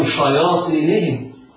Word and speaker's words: شياطينهم: وإذا خلوا إلى شياطينهم، انظر شياطينهم، إياك وأن تقرأ خطأ شياطينهم كما يفعله بعض الناس، لا الشين موققة شا والياء مرققة شياء، والألف تابعة للياء في الترقيق شياء.